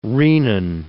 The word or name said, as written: renin